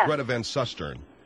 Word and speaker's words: Greta Van Susteren: I thought it was Greta Van Susteren. – Yes. – Isn't that what it is? – That's what I said. Yes. – Greta Van Susteren.